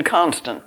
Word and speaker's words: Constant: C-O-N-S-T-A-N-T. It's page 501, but it doesn't give his right name there. Anyway, it would be English in Constant, but what's the French? Constant, I would think. Constant.